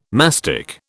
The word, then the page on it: mastic: British English